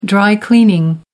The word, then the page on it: dry cleaning: British English